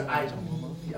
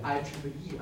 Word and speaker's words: IEEE: 业主业主工程师协会啊什么的，这个上网的课就知道这字怎么读啊，不是 IEE 啊，读 IEEE 啊。